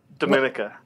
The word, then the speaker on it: Domenica: Dominica.